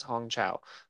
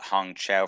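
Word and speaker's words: Hong Chau: I'm going to try to pronounce this. Hong Chao? Chao? I'm just going to say Chao. – Hong Chao. Whale. – Hong Chao. – That is what Brendan says, because he credits all his cast. – Oh, okay. – Every time he's been up, he always credits everyone else in the movie, and he's been pronouncing her name as Hong Chao.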